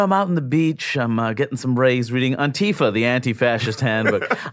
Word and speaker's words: Antifa: Antifa. The emphasis is on the first syllable.